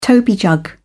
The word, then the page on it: toby jug: British English